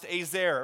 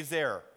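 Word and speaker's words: Azer (עזר): and it's pronounced Azer. Everybody say Azer.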